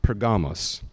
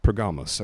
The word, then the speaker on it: Pergamos: So I'm going to go off the Greek pronunciation, which is Pergamos. Pergamos.